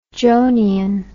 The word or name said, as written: johnian